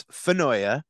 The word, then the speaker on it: Finoia: I'll give you an A minus, but it yeah, great effort. It's Finoia.